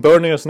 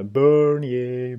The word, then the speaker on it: Bernier: Och så uttalar de Berniers namn bättre än vad vi gör. Det är i och för sig inte så svårt. Men de lyckas ju sätta eh, Bernier, Bernier konstant där.